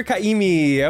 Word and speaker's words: Ka'imi: We have the wonderful, the gorgeous, the hilarious, Hunter Kaimi.